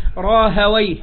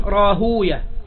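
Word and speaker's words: راهويه: ومنهم منهم مثل إسحاق بن راهوي، احنا يجوز ان احنا نقول راهوي، هكذا يقول اهل النحو، واهل الحديث يقولون راهوية، وهكذا في الاسماء المختومة بويه كلها فيها هذا الاختلاف في النطق.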